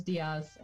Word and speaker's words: Diaz: Um, but yeah, it's Camille, and the last name is Diaz.